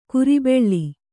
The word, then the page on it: ಕುರಿಬೆಳ್ಳಿ: kuri beḷḷi